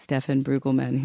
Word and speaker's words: Stefan Brüggemann: I'm incredibly excited to be here with Stefan Brüggemann, whose show Hyper Pompolist.